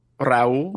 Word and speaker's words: Raúl: All I have to say is a just to follow up on Raul's point. Wait, did I say it right? – No. – Raul? – Yeah, yeah, there you go.